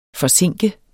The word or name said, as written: forzinke